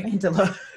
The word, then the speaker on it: Amandla: Is it Amandla? – Yeah. – How the hell do you say her name? Okay. – Amandla.